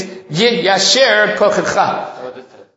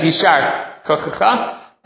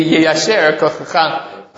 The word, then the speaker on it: yiyasher kochecha: Right, the proper way to pronounce it, and when someone gets an aliyah, somebody does something, is yiyasher kochecha. Right, I know there's a Masoret Sasha, it says yishar kochecha. But the proper pronunciation, probably grammatically in Hebrew, should be Yeyasher Kochecha.